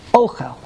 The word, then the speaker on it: Ochel: But how do you say the word Ochel? – Okay. – The Segel. Ochel.